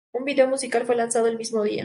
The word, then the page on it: video: Spanish